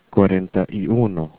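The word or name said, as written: cuarenta y uno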